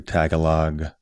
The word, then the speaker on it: Tagalog: If I'm pronouncing things correctly, Tagalog, am I pronouncing that correctly? – Yep.